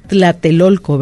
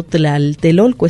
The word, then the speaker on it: Tlatelolco: Es Tlatelolco, ¿verdad? Allá Tlatelolco. Se maneja como Tlatelolco. – Que puede ser, perdón, simplemente una simplificación de la palabra para una por una cuestión de pronunciación, nada más, ¿no? – Sí, y aquí, desde lo que yo sé, siempre había sido Tlaltelolco.